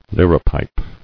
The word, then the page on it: Liripipe: lir·i·pipe; Lir"i*pipe